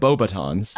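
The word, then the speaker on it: Beauxbatons: T-R-O-G-L-O-T. – Now, I feel like that's what they play at Bobatons.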